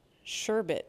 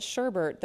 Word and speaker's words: sherbet: I don't like it when people pronounce sherbet wrong. They call it sherbert. There's no extra R in there. There's only one.